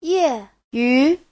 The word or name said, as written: ye4yu2